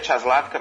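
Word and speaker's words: Čáslavská: Vera Czaslavska, se pronuncia Czaslavska, mas se escreve Kaslavska, com VSK no fim, vale a pena.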